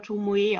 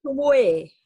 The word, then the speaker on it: Tomoe: Also wenn es japanisch ist, wird es aber Tomoe ausgesprochen.